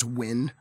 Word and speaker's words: Nguyen: It's I believe it's a Korean name written N-G-U-Y-E-N, but I've always heard it pronounced as Win.